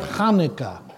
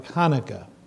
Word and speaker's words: Hanukkah: They don't know how to say the ha. Ready? Practice. Ha. And thus you get Hanukkah.